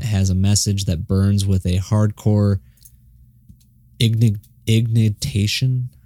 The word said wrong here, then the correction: indignation: I know the word, but I'm fumbling over how to say it. – It's in their Spotify bio. – Yeah. While coupled with progressive instrumentation. – Oh, okay. Indignation.